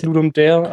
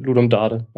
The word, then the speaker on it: Ludum Dare: Ich glaube, international ist es Ludum Dare, aber im Deutschen Ludum Dare.